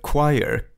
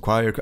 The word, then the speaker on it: choir: Det liksom uttalas inte alls som det stavas och så vidare. Och han satt och kämpade med det där liksom choir, alltså upprepade gång på gång, den här liksom fonologiska loopen som det kallas.